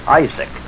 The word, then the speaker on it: Isaac: We say Isaac, but the Hebrew accents the last syllable, Perhaps this is why we have been called Azak's sons, Saxons.